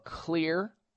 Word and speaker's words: Xlear: So you go to the health food store, you purchase a bottle or two of Clear Nasal Spray, and it's spelled X-L-E-A-R. The X stands for xylitol.